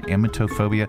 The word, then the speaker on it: emetophobia: Anyway, number three, emetophobia.